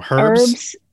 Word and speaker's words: herbs: Oh, herbs. – It's no, you're herbs? Not supposed to pronounce the H. No, yeah, that's wrong. It's herbs. Herbs, not herbs.